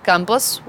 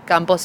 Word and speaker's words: Campos: My name is Delfina Campos, which is spelled D-E-L-F-I-N-A. Campos is C-A-M-P-O-S.